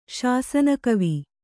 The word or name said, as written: ಶಾಸನಕವಿ